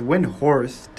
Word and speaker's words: Windhorst: I know it has a T at the end, right? It's Windhorst. But I don't know if people pronounce the T at the end. What is it? Brian Win. Yeah, it's Horst. But I don't think people pronounce the T. I think they say Brian Windhorse.